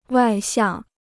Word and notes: 外向: wài xiàng

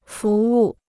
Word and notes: 服务: fú wù

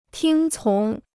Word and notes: 听从: tīng cóng

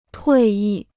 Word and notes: 退役: tuì yì

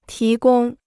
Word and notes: 提供: tí gōng

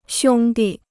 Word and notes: 兄弟: xiōng dì

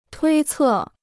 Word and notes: 推测: tuī cè

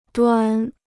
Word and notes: 端: duān

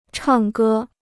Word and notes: 唱歌: chàng gē

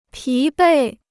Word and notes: 疲惫: pí bèi

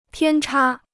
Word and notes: 偏差: piān chā

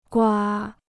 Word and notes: 瓜: guā

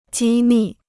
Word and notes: 机密: jī mì

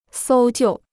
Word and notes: 搜救: sōu jiù

